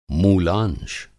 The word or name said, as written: मूलांश